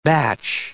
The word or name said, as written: bach